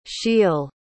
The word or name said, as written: Shiel